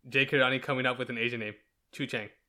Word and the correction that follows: Cho Chang: Cho Chang. Is it Chang? Oh no. Cho Chang.